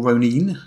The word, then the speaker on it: Ronin: Yeah, so this is from Green Ronin, or Ronin, as they pronounce the word.